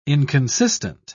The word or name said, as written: inconsistent